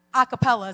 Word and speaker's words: a cappella: Listen, not that many people can bring in the presence of the Lord like that, like a cappella. Is that how you say it? With no instruments? Yeah.